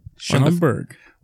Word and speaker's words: Schaumburg: Schaumburg.